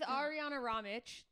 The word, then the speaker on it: Arijana Ramic: You guys, I'm here with Arijana Ramic.